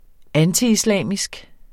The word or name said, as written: antiislamisk